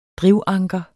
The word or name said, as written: drivanker